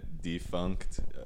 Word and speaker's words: Defunckt: So you can find me at Defunct. So it's like spelled like my name. So it's DEFUNCKT. Because, you know, it's a little funny pun.